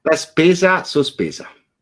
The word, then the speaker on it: La Spesa Sospesa: La Spesa Sospesa. Is that remotely familiar to what you're? – Yes, absolutely. Can we go through? – Okay. – Why don't you pronounce it properly? – La Spesa Sospesa.